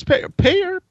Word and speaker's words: Peyer: I'm pretty sure it's Payer, P-E-Y-E-R.